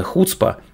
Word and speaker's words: хуцпа: В конце концов, эти израильтяне уже не раз доказывали миру, что их отечественное ноу-хау, израильская хуцпа, американцы именно так это слово и произносят с ударением на первый слог, может сильно всех удивить.